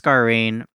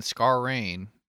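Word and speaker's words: Scarane: I think it's Scarrain. And it, because when I heard her name pronounced, I thought they said Jessica Chastain, but it looks like Scarrain, and Scarrain and Chastain kind of sound similar.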